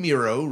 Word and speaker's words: Miro: Our first promo is with John Miro, Rhymes with Hero.